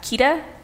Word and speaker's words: Keeta: They can find me at Keeta, K-E-E-T-A, _ Vaccaro, V as in Victor, A-C-C-A-R-O, and my company name is Mizuare Collection.